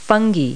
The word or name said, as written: fungi